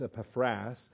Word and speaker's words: Epaphras: In Greek, the accent is over the alpha at the end, so it's Epaphras, a shorter form of Epaphroditus, nickname for Epaphroditus, if you will.